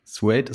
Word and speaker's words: Suede: Suede ist korrekt.